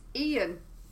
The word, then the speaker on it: Xeeyan: My character is Ian, which is spelled X-E-E-Y-A-N.